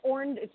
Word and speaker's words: Orndorff: Paul Orndorff. Now, the NWA. – You said it wrong. It's Orn. Say it like Jesse the Body was at Orndorff.